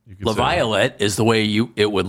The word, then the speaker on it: Laviolette: He pronounces a Y there. – Laviolette.